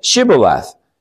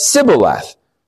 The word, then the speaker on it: Shibboleth: And when he said no, they said to him, "Say Shibboleth." And he said Sibboleth, for he could not pronounce it right.